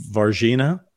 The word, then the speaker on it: Varginha: It's the, how do you say it? Vahinha? – I think... Varginha.